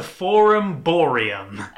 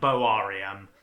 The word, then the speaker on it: Boarium: So the cattle market, I'm going to mispronounce it first because the mispronounced name is much better. It's the Forum Boarium. – Hey! Okay. – It's actually pronounced Boarium.